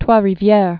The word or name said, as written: Trois Rivieres